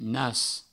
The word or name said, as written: nas